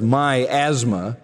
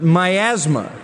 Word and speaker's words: miasma: Not two words, miasma, but miasma. Some of you were looking a little startled for a moment there. The English word miasma comes from this Greek word by the same pronunciation, miasma.